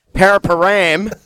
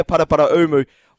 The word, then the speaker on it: Paraparaumu: Paraparam, as a few of the locals say down here, Paraparaumu, beautiful links.